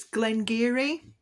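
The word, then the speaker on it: Glen Garioch: It's Glengarry, it's called. And Geary is spelled G-A-R-I-O-C-H.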